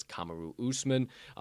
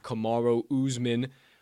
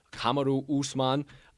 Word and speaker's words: Kamaru Usman: For all of you who are wondering who the hell I'm talking about, it's Kamaru Usman, not Kamaru Usman. Kamaru Usman.